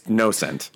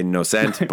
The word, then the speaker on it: Nocent: No. There's no E at the end. It's Nocent, I guess. Some people say Nocent, but doesn't matter.